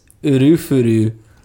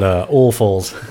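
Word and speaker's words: Ulfuls: Looking more at, I guess it's Urufuru, which is like a bastardization of soulful. Because one of the band members is named Urufuru Kaisuke. So I guess that's how you pronounce the band name. – Not Ulfles. – Ulfles. Ulfles. Awful. – Sounds a lot like awful.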